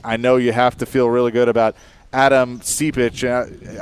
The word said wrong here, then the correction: Adem Sipic: I hope I'm saying his name right, but help me out there. But signing him to the club, I know, is a big deal. – Yeah, Adem Sipic is his name.